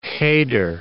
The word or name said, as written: heder